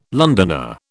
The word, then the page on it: Londoner: British English